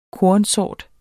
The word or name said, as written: kornsort